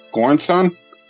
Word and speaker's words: Göransson: Yeah, so best original score was for Oppenheimer, and the composer, and I apologize if I butcher this name, it's Ludwig Göransson. Göransson? – Okay. – Is that how you would say it? – Yeah.